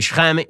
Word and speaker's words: Shechem: Hey, Shechem, or Shechem, as it's pronounced in English, is now an Arab city.